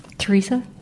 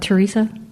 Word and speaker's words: Teresa: I'm Teresa. Teresa. – Treva. – Teresa. – Like Teresa, yeah.